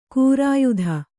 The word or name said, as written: ಖೈರಿಯತ್ತು